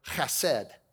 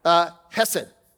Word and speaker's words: chesed: And it's the word chesed. Okay, it's a Hebrew word. I'm not going to say it like that anymore. I may try it one more time. Chesed, okay? We have wonderful, beautiful people on the front row, and if I try it a bunch more times, I might spray it on them. So I'm just going to call it chesed, okay? That's the way gringos pronounce it, just chesed.